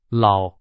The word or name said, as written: 老